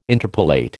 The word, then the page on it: interpolate: American English